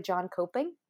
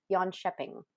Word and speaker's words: Jönköping: Specifically, they are from Jönköping, which when you read it, it looks like John Coping, but I listened to multiple people pronounce it, and I'm pretty sure it's Jönköping, but I'm so sorry if I said that wrong.